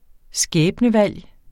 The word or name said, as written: skæbnevalg